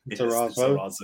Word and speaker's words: Lizarazu: then was it Lizarazu? Is that how you say his name? – Lizarazu. – No, I prefer Lizarazu.